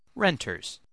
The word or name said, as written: renters